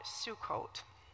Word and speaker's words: Sukkot: So this festival is called Sukkot.